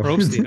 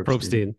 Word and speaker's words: Probstein: Probstein, oh my goodness. Thank you. – If I had a nickel for every time it's mispronounced, but it's Probstein. – Probstein.